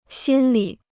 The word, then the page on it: 心里: xīn li